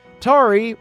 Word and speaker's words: Tari: Tari rhymes with Jabari, Welshlander, Garrett G., a.k.a.